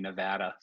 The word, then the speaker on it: Nevada: Now, you know, the thing about Vegas, though, or about Nevada, and, you know, I say it like a Canadian, Nevada, sorry.